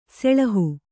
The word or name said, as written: ಸೋಳಹ